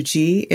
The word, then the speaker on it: qi: And for listeners, that's spelled Q-I, but qi.